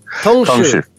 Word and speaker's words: Tongshu: So what, how do you pronounce that? – Tongshu.